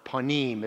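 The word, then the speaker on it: panim (פנים): pana or panim, as it's pronounced in Hebrew.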